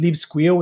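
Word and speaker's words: libSQL: I don't use it in all podcasts or like in any appearances because people are more familiar with either SQL or SQL. But I actually find Squeal Lite and Leave Squeal, which is our fork, just the best pronunciation.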